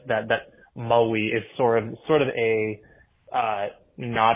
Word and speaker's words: Maui: Americans really flatten out that kind of A vowel sound in it. So, like, in Hawaiian, it is more of like Maui as opposed to Maui.